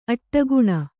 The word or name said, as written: ಅಡಿಗಾಣ್